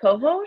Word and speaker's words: cohosh: Black kosho? – Black cohosh. It's How do you spell it? – black COAO. COHOSH, black cohosh.